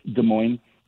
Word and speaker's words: Des Moines: How do you say Des Moines, where you live? – Officially, it's Des Moines. – Des Moines. – On the street, it's Des Moines.